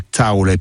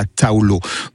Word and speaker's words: taula: Voilà, vous voyez la taula et pas la taulo.